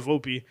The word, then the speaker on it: Volpe: Yeah, they call it Volpe. But if I was to read it on paper, I would just be like Volpe.